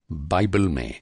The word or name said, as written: बाइबल